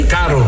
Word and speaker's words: caro: Falei caro. Caro, mas eu não pronunciei bem o R. Falei caro.